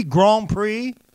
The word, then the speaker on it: Grand Prix: 54, that's Grand Blanc Prix. Grand Prix, Grand Prix, as it's pronounced.